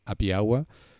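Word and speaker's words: Happy Awa: Recomiendo Happy Agua. agua discúlpame, que se, que se escribe. Happy agua es la transliteración claro. del término como happy hour. Pero que se escribe happy como feliz y agua con A-W-A.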